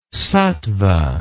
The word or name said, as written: sattva